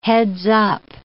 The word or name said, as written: heads-up